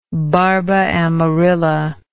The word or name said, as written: barbaamarilla